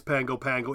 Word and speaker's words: Pago Pago: The Samoans have kept that N off, so it is Pango Pango, even though it's spelled Pago Pago.